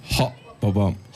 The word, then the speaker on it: Hababam: Viel Spaß mit Sultan Tunç und dem von mir schlecht ausgesprochenen Titel Hababam. Hababam? – Hababam.